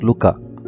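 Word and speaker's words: lykke: The Wow word of the week is the Danish word pronounced Luka.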